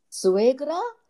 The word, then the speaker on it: suegra: You were talking about you might have an overbearing, forgive my pronunciation, suegra. – Yes, very good.